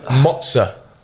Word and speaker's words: mozza: It's mozza. – Mozza. – No. There's no N in it.